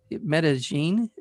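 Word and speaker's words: Medellín: But I want to let our audience know that A Boy from Medellin, you say differently than I have been saying it. So is it Medellin? Is that right, Matthew? – That's how you're supposed to say it, yeah.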